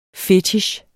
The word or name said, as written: FETICHENS